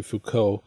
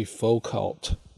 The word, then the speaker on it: Foucault: I should mention, I learned unfortunately that he uses the American pronunciation like so many unfortunate souls do. So while his name should be Jeffrey Foucault, he calls himself Jeffrey Foucault, which is just plain wrong.